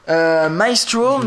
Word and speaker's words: Mycestro: Le projet, c'est un projet Kickstarter qui s'appelle Maestro. Maestro, mais alors ça s'écrit M-Y-C-E-S-T-R-O.